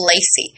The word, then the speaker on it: Lacey: But there's a song called Lacey,